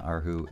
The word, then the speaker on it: erhu: Ren, thank you very much, on Arhu and Yangqin. Did I say that right, more or less? – Very good, yeah.